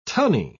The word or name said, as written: tunny